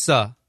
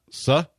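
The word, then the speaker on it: Seo: And would it be Jane So? Would that be how you pronounce it? – It's Suh. – Suh? – Yeah, in Korean, it's Suh. Sun with S-U. It's like Suh without the N. – Okay, it's S-E-O is her name.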